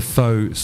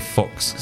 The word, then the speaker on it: Faux: Before COVID, it was just a nightclub called the Faux Social Club. And you know how a lot of people couldn't pronounce Faux, and they used to call it the Fox Social Club.